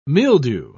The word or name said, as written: mildew